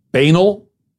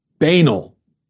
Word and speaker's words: banal: Banal? Banal. Okay, it's banal. It's not banal. Banal rhymes with canal. It's not banal rhymes with anal.